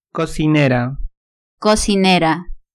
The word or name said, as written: cocinera